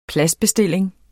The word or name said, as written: PLADSBESTILLING